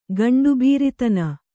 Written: ಗಂಡುಬೀರಿತನ

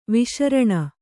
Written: ವಿಶರಣ

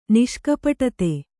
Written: ನಿಷ್ಕಪಟತೆ

